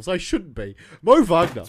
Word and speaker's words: Wagner: Wagner. – Yeah. – God damn it. That's right. That's right. Yeah. – It's like Arsene Wenger.